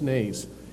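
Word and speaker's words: Nace (נס): The root word for Nisi is Nace, N-E-C, Nace, pronounced Nace, 5251.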